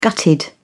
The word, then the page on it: gutted: British English